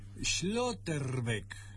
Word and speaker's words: Schlotterbeck: A este le van a decir Nico, es alemán, pero le van a decir Nico porque es Schlotterbeck. No es tan complicado, tan parecido al inglés. Schlotterbeck.